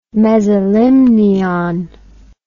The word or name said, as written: mesolimnion